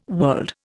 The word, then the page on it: world: Indian